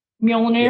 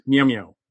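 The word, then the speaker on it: Mjölnir: Mjolnir. – Yes. Mjolnir.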